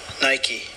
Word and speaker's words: Nike: Nike.